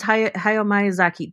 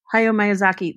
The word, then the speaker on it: Hayao Miyazaki: And he loves Hayao Miyazaki. Did I just butcher that name? – No, you did not, actually. No, you got it right. – Hayao Miyazaki, that's right.